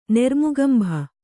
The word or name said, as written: ನೆರ್ಮುಗಂಭ